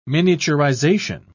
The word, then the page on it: miniaturisation: British English